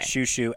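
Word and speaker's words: Xiu Xiu: Like Shushu, XIU, XIU, and then the album is just Nina.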